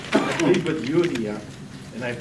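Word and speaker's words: Euodia: Yeah, it's Eudia and Syntyche.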